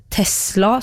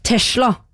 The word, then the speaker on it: Tesla: Ok, og så det en anonym her som irriterer seg over folk som uttaler Tesla som Tesla. Det gjør jo jeg da, jeg sier Tesla. – Ja. – Det stemt S, burde være mulig, så man skal da si Tesla.